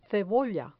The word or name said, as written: cebolla